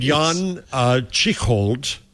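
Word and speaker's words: Jan Tschichold: Meanwhile, there's a guy named Jan Chichold. I'm going to mispronounce his name. – You pronounced it exactly correctly, though.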